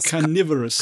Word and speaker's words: carnivorous: Car wie spricht man das aus? Carnivorous. – Äh, Carnivorous.